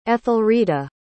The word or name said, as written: ethelreda